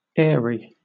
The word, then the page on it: airy: British English